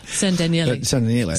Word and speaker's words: San Daniele: San Daniele. – San Daniele.